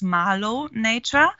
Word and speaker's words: Marlow Nature: Platz zwei ist Marlow Nature oder Natur, wie man es aussprechen mhm.